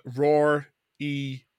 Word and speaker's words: Rory: Roar E Cashin, spelled pretty phonetically there. Yeah. – So as in like a lion's roar.